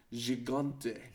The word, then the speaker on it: Gigante: And then all six men climb into the ring, but the horsemen clear it when Gigante. Ta, going hard G.